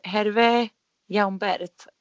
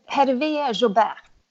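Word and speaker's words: Hervé Jaubert: Ursäkta min franska här. Hervé Jaumbert. – Hervé Jaumbert. – Ja, det var bättre.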